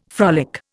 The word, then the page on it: frolic: Indian